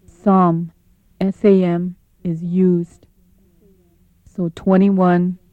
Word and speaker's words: sam: Sam is pronounced as sum.